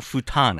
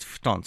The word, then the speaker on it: futon: You know, it's also like, it's not futon, it's futon.